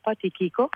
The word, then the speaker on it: Tichico: Sì, credo che fondamentalmente già il titolo si pronuncia con la K, come una K, Tichico, ma ha ragione ad avere difficoltà, avete tutti ragione ad avere difficoltà nella pronuncia.